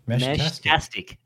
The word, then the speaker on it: Meshtastic: What is it? How do you say that? – Meshtastic. Oh yeah, there you go. I think he misspelled it, it. It's meshtastic. – Yeah. Yeah, meshtastic. Meshtastic.